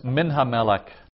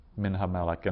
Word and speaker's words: Minha Melech: So there's only one stressed syllable in that whole unit, Minha Melech. And so, and in this case, the accent would be on the Meh of Melech.